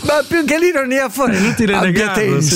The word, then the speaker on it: abbiatensi: Abbiate Grassesi? – Non lo so, però io ogni volta...